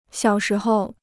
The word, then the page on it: 小时候: xiǎo shí hou